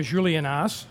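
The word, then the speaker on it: Juliénas: Now, you see that the biggest word on the label here is Julienas, or Julienas, as it's pronounced.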